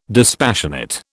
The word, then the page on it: dispassionate: British English